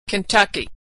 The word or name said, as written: Kentucky